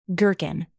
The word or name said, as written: gherkin